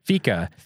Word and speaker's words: fika: And that name, Fika, am I saying it right? – No, Fika. – Fika. – Yeah, it's Swedish for coffee.